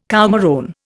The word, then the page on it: coumarone: Indian